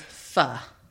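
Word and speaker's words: Phở: The way that you're supposed to pronounce it is Fa.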